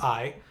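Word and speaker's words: Ay: A couple of them that we know really well were a vizier, whose name was just I. Which is a refreshing breath of fresh air. Was he represented hieroglyphically as an I? – It's spelled A-Y.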